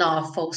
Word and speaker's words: awful: It died with an awful sound. Died with an awful sound. Então, esse awful se escreve com AW, mas tem um som de um O com acento agudo.